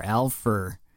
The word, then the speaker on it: Alfur: It is spelled A-L-F-U-R, Alpha.